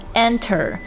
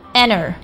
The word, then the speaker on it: enter: 这个单词呢，我们可以按照字典上的这个音标给它读成 enter，也可以直接把这个里面的字母 t 发的 t 的音给它省略掉，读成 enter。